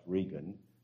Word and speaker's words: Reagan: spelt like Reagan but pronounced Regan from Texas,